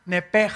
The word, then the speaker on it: nepesh: Apúntalo ahí en tu Biblia, esa palabra alma es la palabra hebrea nepesh, así como suena, N-E-P-E-S-H, nepesh. La palabra, la letra o las letras SH en el hebreo se pronuncian en hebreo como J. Entonces se pronuncia Nepej.